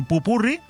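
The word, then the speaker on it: popurrí: y hacemos un buen pupurrí, con acento en la I, para que el fin de semana, sábado y domingo, también los disfrutes.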